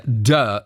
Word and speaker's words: duh: Ja, duh heißt das, wird also so ausgesprochen. – Ach, du hast immer du gesagt, entschuldige.